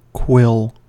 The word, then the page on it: quill: American English